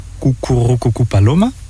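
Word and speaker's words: Cucurrucucú Paloma: C'est Cucurru Cucu Paloma. Je le dis bien. – Bravo.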